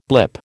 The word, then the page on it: blip: American English